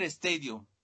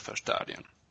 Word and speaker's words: Stadion: A ver, aquí lo tengo, voy a decirlo mm, con Mungersdorfer Stadium. – Stadion.